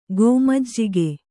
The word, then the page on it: ಗೋಮಜ್ಜಿಗೆ: kannada